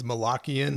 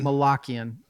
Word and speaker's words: Malakian: Do you pronounce it Malakian? – I think it's Malakian. – Malakian. I think you're right.